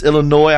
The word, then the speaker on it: Illinois: It's Illinois. I'm not, the S is silent.